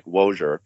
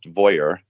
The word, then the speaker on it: Wojoer: They're a company in Germany, and it's spelled W-O-J-O-E-R. It looks like Wojer, but it's pronounced Voyer.